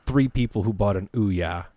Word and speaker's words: Ouya: Ouya. – Ouya.